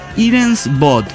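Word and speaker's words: Hiren's Boot: Hirus. No, se llama Irens Bot. Se escribe H-I-R-E-N-S Bot, B-O-O-T.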